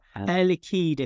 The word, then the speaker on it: Air Liquide: Yeah, well, Air Liquide, if you want to.